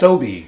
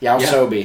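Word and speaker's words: YOASOBI: I know out of Oshinoko by, I don't know how to pronounce Yaosobi. – It. Yaosobi.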